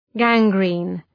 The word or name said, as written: gangrene